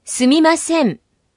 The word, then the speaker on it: すみません: Sumimasen. – La parte final de se pronuncia alargando un poco la vocal o.